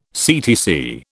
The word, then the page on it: CTC: British English